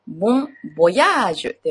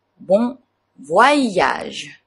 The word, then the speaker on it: bon voyage: フランス語特有ですね。こちらで、ボン・ボヤージュではなく、ボン・ヴワイヤージュ。ヴワイヤージュというのがポイントです。ボン・ヴワイヤージュ。